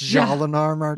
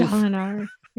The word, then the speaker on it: Jolinar: So you do this weird Jalinar. It's pronounced Jalinar, too. – Jalinar. Yeah, it's very French.